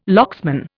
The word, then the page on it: locksman: Indian